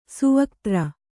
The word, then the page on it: ಸುವಕ್ತ್ರ: Kannada